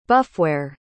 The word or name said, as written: buffware